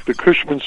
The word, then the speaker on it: Cushman: Yeah, like the Cushman scooter.